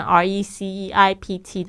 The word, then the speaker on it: receipt: receipt 特别注意一下，它虽然是拼成 R E C E I P T，但是那个 P 不发音哦。